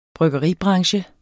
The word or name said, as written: bryggeribranche